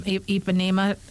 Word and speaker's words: Ipanema: Ipanema.